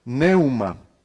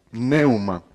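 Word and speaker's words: neuma (πνεῦμα): En griego, espíritu se dice y se escribe neuma, neuma, empezando con una P, neuma. Esta palabra neuma, la P, la N, la E, la U, la M y la A, neuma, que significa espíritu, nosotros le encontramos por lo menos 379, 379 veces en los 27 libros del Nuevo Testamento.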